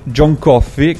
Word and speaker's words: John Coffey: Quella che state per sentire è la canzone che John Coffey, che si pronuncia come la bevanda, dice lui, ma è scritto in modo diverso, canta per farsi forza quando è sulla sedia elettrica e deve essere giustiziato ingiustamente.